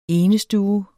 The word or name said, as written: enestue